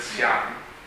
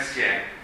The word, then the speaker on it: 想: Quindi, per esempio, D-I-A-N si pronuncia DN, se aggiungiamo la R alla fine si pronuncia DIAR. Volere, desiderare, per SIAN. Non è sien, ma è sian, perché c'è la G alla fine.